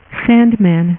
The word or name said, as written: sandman